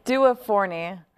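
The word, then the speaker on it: Due Forni: But this, ladies and gentlemen, is Duvernay Pizza, and it's awesome. You got to go there. – Okay, it's Duo Forni. – And I said it wrong to the guy.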